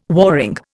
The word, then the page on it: warring: Indian